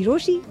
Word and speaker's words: Rochie: मेरा नाम है मिर्ची रोशी,